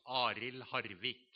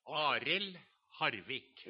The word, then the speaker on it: Arild Hardvik: And the pastor, he was quite good pronouncing it in Norwegian. It sounds Arild Hardvik.